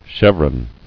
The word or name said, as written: Chevron